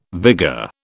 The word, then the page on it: vigour: British English